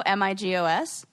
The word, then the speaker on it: Migos: Will you spell that? – Rap trio, Migos. – Oh, it really is like Amigo. Okay.